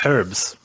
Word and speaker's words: herbs: Your herbs. – Which is how you say it, because there's a fucking H in it.